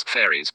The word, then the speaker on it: Faroes: There is a group of Danish islands off the coast of Scotland that are called the Faroes, pronounced by some as fairies, pointing to possible descent from Pheres, one of Judah's twin sons.